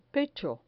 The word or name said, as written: pecho